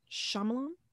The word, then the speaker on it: Shyamalan: Night Shyamalan. Did I say that right? Shyamalan? – Yeah.